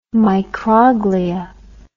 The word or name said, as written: microglia